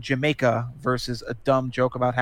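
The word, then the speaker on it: D'yer Mak'er: I think the other one, it took me years to figure out how to pronounce the name Jamaica, which is how you pronounce that song. I used to call it Deer Maker or whatever.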